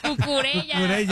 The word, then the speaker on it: Cucurella: No, pero se pronuncia Cucuyella. Ya no puedo. Cucurella.